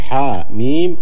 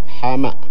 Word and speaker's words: حم: إذن فنحن في الكلام والقراءة ننطق بمسمى الكلمة ولا ننطق باسمها، ففي حاء ميم ننطق بالاسم لا بالمسمى، حاء ميم وليس حماء.